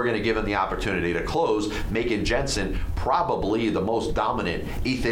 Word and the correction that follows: Jansen: I'm sorry, Jansen, Kenley Jansen. Let's get the name right.